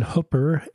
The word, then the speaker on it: Hooper: She grew up in Hooper, H O O P E R, Utah.